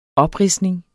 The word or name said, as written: opridsning